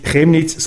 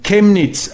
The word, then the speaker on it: Chemnitz: Ich würde Chemnitz falsch aussprechen. Chemnitz, die Stadt in Sachsen, werde nicht Chemnitz, sondern Chemnitz ausgesprochen.